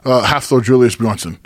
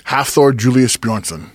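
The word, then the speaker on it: Hafþór Júlíus Björnsson: Half-Thor Julius Bjornsson. Just call him Thor. – Say it again. – Half-Thor Julius Bjornsson.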